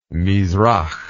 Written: mizrah

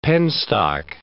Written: penstock

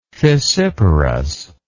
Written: fissiparousness

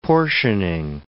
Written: portioning

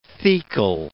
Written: thecal

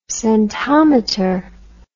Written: scentometer

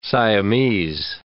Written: Siamese

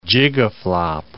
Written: gigaflop

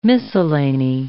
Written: miscellany

